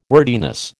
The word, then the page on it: wordiness: American English